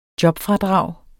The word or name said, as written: jobfradrag